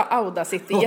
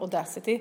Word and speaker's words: Audacity: Vi sa Audacity jättelänge för att vi bara läste. Men när vi hörde några som pratade i en annan podd så sa de Audacity.